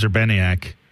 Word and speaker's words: Urbaniak: James Urbaniak.